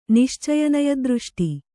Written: ನಿಶ್ಚಯನಯದೃಷ್ಟಿ

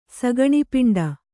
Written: ಸಗಣಿಪಿಂಡ

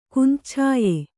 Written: ಕುಂದಚ್ಛಾಯೆ

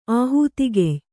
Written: ಆಹುತಿಗೆಯ್

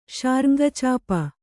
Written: ಶಾರ್ಙ್ಗಚಾಪ

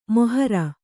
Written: ಮೋಹರಿ

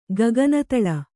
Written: ಗಗನತಳ

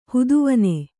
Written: ಹುದುವನೆ

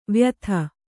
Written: ವಾಯಿದೆ

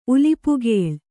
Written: ಉಲಿಪುಗೇಳ್

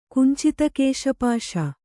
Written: ಕುಂಚಿತಕೇಶಪಾಶ

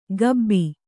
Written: ಕಬ್ಬಿ

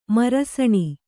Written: ಮರಸಣಿ

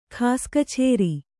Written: ಖಾಸ್‍ಕಛೇರಿ

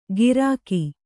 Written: ಗಿರಕಿ

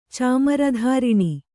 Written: ಚಾಮರಧಾರಿಣಿ

